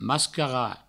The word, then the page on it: mascarar: mascara